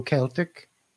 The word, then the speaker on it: Celtic: in which case we'd be watching Glasgow Celtic putting on a show. Since the mid-1900s, the hard C's become the predominant usage. So Celtic is the way we say the adjective.